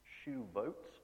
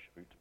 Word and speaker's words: Shavuot: This holiday, because that was known as weeks, but the Hebrew word for this is shuvot, a practice saying that. But it's spelt Shavuot, so I'm just going to keep saying Shavuot by accident.